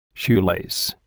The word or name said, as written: shoelace